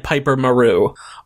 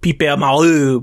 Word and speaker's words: Piper Maru: Um, also, if they didn't, if they had not said, it, um, if they had not said Piper Maru, I definitely would have tried to pronounce it like Pipe Malou because they're French.